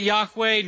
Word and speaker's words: Yahweh: In the 16th century, somebody thought maybe we pronounce it Jehovah. Same word, but we know that it was probably never pronounced Jehovah. It was something like Yahweh.